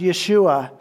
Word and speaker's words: Yeshua: In Hebrew, it's pronounced Yeshua, and it means Savior.